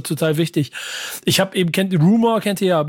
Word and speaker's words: RMR: Der wird Rumor ausgesprochen. – Ja, genau. – Jobsy hat es mir auch gesagt, dass er eigentlich Rumor heißt. Ich habe es aber wieder verdrängt und immer nur RMR.